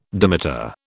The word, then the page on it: dimeter: British English